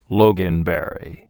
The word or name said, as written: loganberry